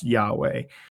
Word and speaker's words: Yahweh: And over the years, we've like over the centuries, we've come to to the conclusion that that is Yahweh and said and and pronounced Yahweh.